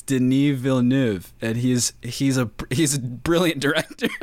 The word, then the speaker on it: Denis Villeneuve: We're talking about Denis Villeneuve. Can you pronounce his last name? – It's Denis Villeneuve. – Yeah, which Denis is Villeneuve. Not even close to how it's spelled.